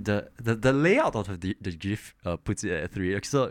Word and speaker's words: GIF: I like to say GIF with a soft G.